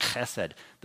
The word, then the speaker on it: chesed: There's our, one of our favorite Hebrew words, chesed again, H E S E D, pronounced chesed, the steadfast love of God, and in his mercy, verse 19.